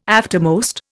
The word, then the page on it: aftermost: Indian